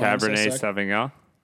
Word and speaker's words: Cabernet Sauvignon: How do you say this shit? Cab Sauvignon or some shit? I don't know how to pronounce that anymore. – Cabernet Sauvignon? – Yeah, there we go.